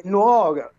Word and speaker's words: Nuoro: È Nuoro, e come ha detto la signora, ha detto bene, perché poi sardo io non lo sono, e quindi quella pronuncia.